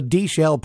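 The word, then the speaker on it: Deeshell: That's D-E-E-S-H-E-L-L. The D-Shell Polka. And D rhymes with B.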